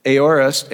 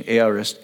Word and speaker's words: aorist: Aorist, I guess is the proper pronunciation. Aorist.